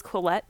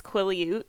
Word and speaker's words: Quileute: which I don't know why I had in my head was Quileute, but I think it's Quileute,